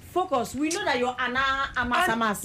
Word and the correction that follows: Ana de Armas: You say Ana Amas Amas. – What? Pronounce that name where Ana de Armas.